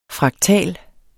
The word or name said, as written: fraktal